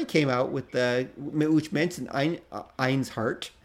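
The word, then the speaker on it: Ainshart: So it's A-I-N-S-H-A-R-T, okay? And if you, now pronounce that as one word. Einsheart is actually how people pronounce it.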